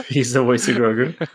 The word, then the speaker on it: Grogu: Pronounced Grogu, not Grogu. Grogu.